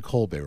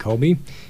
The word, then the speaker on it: Kolbe: It's obviously named after Saint Maximilian Kolbe, right? – Right. Yeah, it's spelled K-O-L-B-E, so not like the cheese.